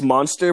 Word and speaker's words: Munster: It sounds like it. Monster. – Monster. – It's Monster, but it's so good.